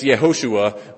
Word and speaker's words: Yehoshua: But the name Joshua, which I'll keep on pronouncing Joshua, if you look in your text, it's Yehoshua, but habit is going to form my language this morning.